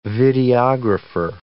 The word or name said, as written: videographer